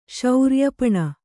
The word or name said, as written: ಶೌರ್ಯಪಣ